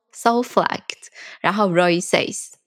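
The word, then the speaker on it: Soflec_Roysays: 然后他的 IG 账号叫做 Soulflect，然后 Roy says，我应该没有发音错吧？ – 没有没有发音错。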